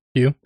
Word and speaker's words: Pugh: Florence Pugh. – Pugh.